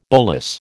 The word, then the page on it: bolus: American English